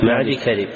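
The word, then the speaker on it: معديكرب: ليس معدي يكذب، مقدام بن معدي كذب، ابن معدي كذب، تنطق تكتب موصولة وتنطق مفصولة، ليس معدي يكذب إنما معدي كذب.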